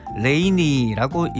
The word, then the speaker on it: LANY: 원래 그러면 LANY 요렇게 읽어야 될 텐데요. 그것보다는 마치 사람 이름인 것처럼 원래 존재했던 단어인 것처럼 레이니라고 읽자라고 해서 팀 이름이 레이니가 됐다고 합니다.